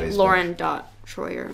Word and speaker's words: Loryn Troyer: And then on Instagram, it's like Loryn.Troyer.